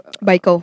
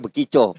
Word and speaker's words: Baiko: Baiko. Baiko. – Baiko. Gue bilang bekico. – Kalau gua bekico. bekico. Yeah. – Bekico. Karena bacanya kan kita gitu, bekico kan. Ternyata Baiko.